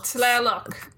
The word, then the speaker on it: Tlaloc: is when you see a word, you pronounce every letter. – Okay. – So it's T-L-A-L-O-C. – Tlaloc. – Tlaloc. Yeah, you're there.